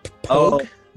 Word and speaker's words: POG: What is it's a POG pronounced pogue? – Pogue.